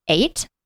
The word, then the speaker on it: eyot: It's spelled E-Y-O-T, but you say it like eight.